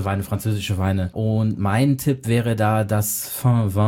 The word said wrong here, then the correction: Vin Vin: Habe ich das schon wieder falsch ausgesprochen? – Vin. – Also mein Tipp wäre dann das Vin Vin.